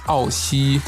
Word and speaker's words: Ao Xi: Es wird geschrieben S-C-O-R. So findet ihr ihn auf Instagram. Wie ist der, die chinesische Aussprache? A-O-X-I geschrieben, glaube ich. Genau, das ist ja S-C-O-R.A-O-X-I und dies, das ist Ao Xi.